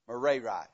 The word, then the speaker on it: Merari: It's Merari. That's how you say it. Merari.